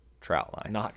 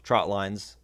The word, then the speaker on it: trot line: So as you're talking about trot lines, share that message that it's a trot line. This is a trot line that we're setting today.